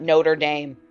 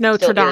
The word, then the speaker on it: Notre Dame: And also, guys, it's not Notre Dame. – It's Notre Dame.